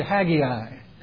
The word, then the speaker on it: Haggai: Now, if we were in Louisiana, or Louisiana, as they say, we'd say, would you turn to Haggai? We put an extra syllable in there just for the fun of it. But it's only two syllables.